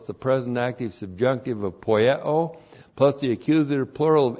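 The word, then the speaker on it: poieo (ποιέω): And then we have a verb poieo. P-O-I-E, long O, poieo.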